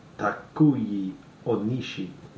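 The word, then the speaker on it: Takuya Onishi: And you've got Takuyi Onishi.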